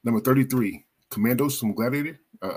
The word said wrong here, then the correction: Commodus: Commodus from Gladiator.